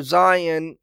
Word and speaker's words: scion: And the way you render Zion in English, it is spelled this way, S-C-I-O-N.